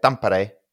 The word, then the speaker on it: Tampere: But that's how they say it, Tampere.